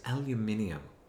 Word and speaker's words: aluminium: Nine is pottery. 10 is aluminium. – Or aluminum, as we say.